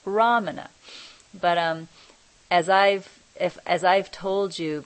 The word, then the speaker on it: Ramana: If for those of you who don't know, it's not Ramana, which sounded like Ra-ma-na. It's Ram-ana, Ramana.